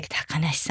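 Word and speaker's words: 小鳥遊: 鷹っていうのは小鳥をこう食べちゃう、小鳥とか小さい動物を食べちゃう鳥ですから、鷹がいなければ小鳥が遊べる、だから漢字で小鳥が遊べると書いて高梨さんと読むそうです。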